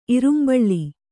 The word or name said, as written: ಇರುಂಬಳ್ಳಿ